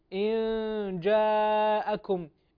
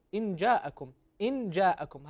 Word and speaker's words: إن جاءكم: إن جاءكم النون الساكنة هنا تنطق مخفاة، أي بمرتبة بين الإظهار والإدغام كما يقول علماء التجويد والقراءات، أن تنطق هذه النون بصفة بين الإظهار والإدغام، هكذا نقول. إن جاءكم أما أن تقلت إن جاءكم هكذا أظهرت النون الساكنة.